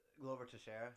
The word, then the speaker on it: Glover Teixeira: Glover Teixeira.